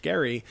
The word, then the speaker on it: Gerry: The first one was up in Massachusetts back in the 1800s, and there was a politician named Elbridge Gerry, who. That's the actual pronunciation. It's changed over time.